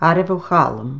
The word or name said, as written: Arivoichallum